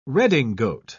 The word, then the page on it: redingote: British English